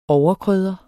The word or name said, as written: overkrydder